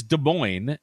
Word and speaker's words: Des Moines: So, hey, real quick, I wanted to say on the January 1982 episode, I evidently pronounced Des Moines, Iowa, very, very wrong. It is Des Moines, as I know.